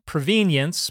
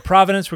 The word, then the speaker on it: provenance: Gonna talk about the word provenance, which some people pronounce provenience, but I prefer to pronounce it provenance.